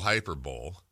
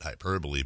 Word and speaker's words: hyperbole: And I'm not a big fan of the old Hyper Bowl, not to be confused with the Super Bowl. Some people pronounce it hyperbole, but I can't help but look at the first two games and go, wow, this series is over.